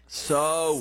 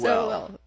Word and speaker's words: Sowell: Sowell. – Sowell.